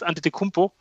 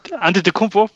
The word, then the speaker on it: Antetokounmpo: Giannis Antetokounmpo. Das sind so die beiden klaren Favoriten auf den, auf den MVP Award und ähm, starke Aussprache, starke Aussprache. Antetokounmpo. Ja, stark. Boah.